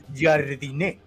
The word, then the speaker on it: Jardine: El, fulano Baños le preguntó, y bueno, ¿cómo se dice? Jardiné, Jardiné. Y yo, dije, todos dijimos todas las versiones, menos como él mismo se llama. Entonces se supone que es Jardiné.